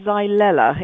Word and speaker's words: Xylella: I pronounce it Xylella. It's spelled X-Y-L-E-L-L-A, but I'm sure your pronunciation is fine as well, Peter. – Well, it's trying to get that first L in, I find quite difficult. X-Y-L-E-L-L-A. Now, can you tell us what is it and what does it attack?